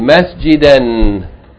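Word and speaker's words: Masjida (مسجدا): But if you stop on it, pause, you don't say Masjidan. You don't pronounce the Tanween. Rather, you pronounce Fatha elongated by Aleph. This Aleph is read as a long vowel, Masjida.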